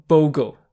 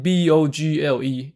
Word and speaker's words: Bogle: 这位 CHXX 版友利用推文告诉我，我第一集节目里圣人杰克的英文名字 John Bogle 哦，他的姓 B O G L E 应该念成 Bogle，不是 Bogle 哦。他说啊，我一直念错，所以导致他很出戏。对不起。对于这个英文的发音错误哦，我没有在录制之前查证清楚哦，是我的不对。那在这边帮大家 refresh 一下你的脑袋哦，John Bogle，John Bogle，John Bogle，哦，连续念三次，重新开机哦，帮大家 brainwash 洗脑一下。